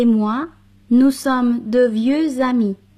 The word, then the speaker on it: amis: Jean et moi, nous sommes de vieux amis.